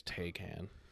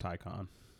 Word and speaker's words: Taycan: I've only ever heard it sold pronounced Taycan. But it could be. It's T-A-Y-C-A-N. – Yeah. – Like, I could be mispronouncing it, but, just, like, that's how I've only heard it pronounced.